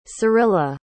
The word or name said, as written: cyrilla